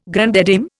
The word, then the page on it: grande dame: Indian